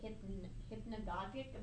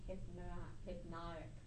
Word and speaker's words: hypnagogic: So is it hypnagogic? That doesn't sound right. So I thought the G was silent, so I was saying hypnotic.